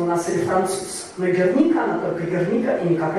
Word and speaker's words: Герника: Герника – это именно правильное ударение, потому что это испанский топоним, это название маленького баскского города, и сложившееся в России слово «Герника» – просто некая ошибка.